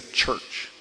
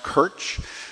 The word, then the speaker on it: church: So the word, now the common church words, I mean, first we would say church. Well, shouldn't church be pronounced kirch? Well, yeah, no.